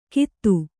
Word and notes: ಕೀತು: kītu

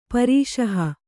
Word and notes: ಪರೀಷಹ: parīṣaha; Kannada

